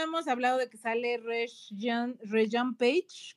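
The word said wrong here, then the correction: Regé-Jean Page: Reggie John Page, ajá.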